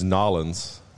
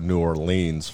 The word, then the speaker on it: New Orleans: It's Nolens, or you go New Orleans for like the more like Kansas.